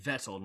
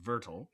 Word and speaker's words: Vettel: Well, and Vettel, not Vertel.